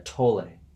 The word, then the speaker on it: Tolle: Oh, they say that last e is not is not silent. Okay, yeah, Tolle.